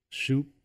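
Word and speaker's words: Schoop: Shoop, baby, shoop.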